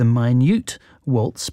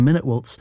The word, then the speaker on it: Minute: It was originally named the Minute Waltz, but we tend to mispronounce it as the Minute Waltz.